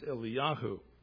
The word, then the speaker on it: Eliyahu: So, and that is pronounced Eliyahu.